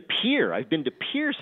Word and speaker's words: Pierre: I've been to Pierre, South Dakota, because I like collecting state capitals.